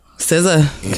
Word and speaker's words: SZA: How you say her name? SZA? – SZA.